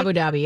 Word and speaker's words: Abu Dhabi: But apparently, the director, Christopher McQuarrie, posted on Instagram that after they wrapped up in Abu Dhabi, how do you say that? Am I saying that right? – Abu Dhabi, yep.